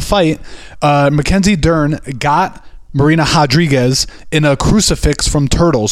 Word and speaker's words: Rodriguez: With an R. But a huh. – But it's pronounced Rodriguez.